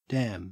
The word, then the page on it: dam: Australian English